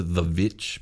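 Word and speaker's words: The VVitch: I some people might pronounce it The Witch because it's two V's, but it's... Really, just pronounced The Witch.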